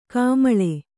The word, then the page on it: ಗೋಮಾಳಿ: gōmāḷi